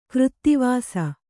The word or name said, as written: ಕರಿದಿವಸ